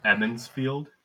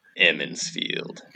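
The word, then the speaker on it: Emond's Field: And I'm going to correct your pronunciation real quick. Emmonsfield. – No long E? – No. – Emmonsfield.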